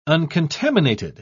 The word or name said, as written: uncontaminated